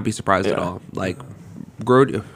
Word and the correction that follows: Grady: I said his name wrong again. – Grady.